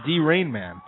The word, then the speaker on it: Draiman: Oh, she said his name wrong. That must be embarrassing. – What did she say? – David Draiman. It's D. Rainman.